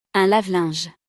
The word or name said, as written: Un lave-linge